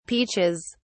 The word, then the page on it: peaches: British English